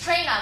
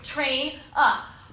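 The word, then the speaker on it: Traina: My name's not Megan. It's Michelle. It's train up. Like, took it, train up.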